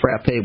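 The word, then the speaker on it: frappé: and it's which is actually pronounced Frappe, which means to hit.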